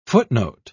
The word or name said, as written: footnote